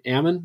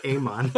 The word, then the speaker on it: Amon: The next king, Ammon, is that how you say it? Or am I just being Mormon? – Go for it. Or Amon. Yeah, either way.